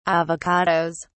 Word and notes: avocados: British English

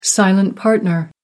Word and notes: silent partner: American English

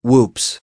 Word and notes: whoops: American English

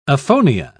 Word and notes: aphonia: British English

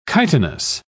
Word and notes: chitinous: British English

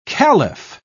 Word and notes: khalif: British English

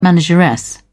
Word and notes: manageress: British English

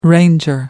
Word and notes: ranger: American English

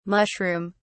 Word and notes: mushroom(s): British English

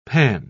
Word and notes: panne: British English

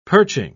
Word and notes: perching: British English

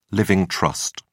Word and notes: living trust: British English

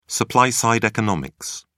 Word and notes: supply-side economics: British English

